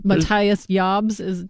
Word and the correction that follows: Jabs: Matthias Jabs of the Scorpions.